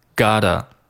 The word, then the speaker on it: gotta: 那这个 got 和后面的这个 a 可以连读，读成 gotta， gotta。